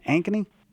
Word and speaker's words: Ankeny: Ankeny? – Yep.